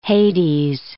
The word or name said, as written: Hades